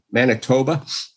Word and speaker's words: Manitoba: And then we got Winnipeg, Manitoba. Yep. I'm saying that wrong, right? – No, Manitoba's right.